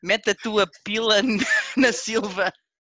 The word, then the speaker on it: pila: Pillar in Portuguese, spelt P-I-L-A, or pronounced pila, means dick.